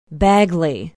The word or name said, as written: bagley